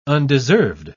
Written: undeserved

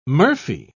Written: murphy

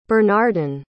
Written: bernardyn

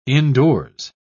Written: indoors

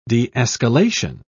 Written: de-escalation

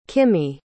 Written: kimmy

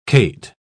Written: cate

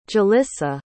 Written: jalissa

